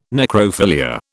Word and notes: necrophilia: British English

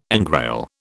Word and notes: engrail: British English

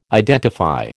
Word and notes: identify: American English

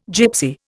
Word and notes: Gypsy: Indian

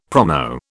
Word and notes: promo: British English

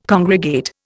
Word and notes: congregate: Indian

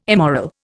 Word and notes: immoral: Indian

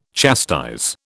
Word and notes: chastise: British English

